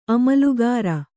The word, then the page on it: ಆಮೂಲಾಗ್ರ: Kannada